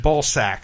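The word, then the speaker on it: Balsac: And I guess it's actually pronounced Balsack, the Jaws of Death.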